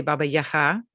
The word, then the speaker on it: Baba Yaga: So when I speak to Ukrainians or Ukrainian speakers, I say Baba Yaga, which is the pronunciation in Ukrainian.